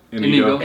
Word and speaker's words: Inigo: Inigo.